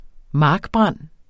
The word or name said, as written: markbrand